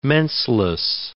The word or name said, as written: menseless